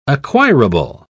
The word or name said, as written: acquirable